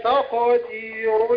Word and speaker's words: تقدير: ذلك تقدير العزيز العليم. – وخلص التاء من القاف يعني رققها وتدخل على تفخيم القاف. قول تاقى مش طاء طاء كده داخل بطاء انت يا تاء.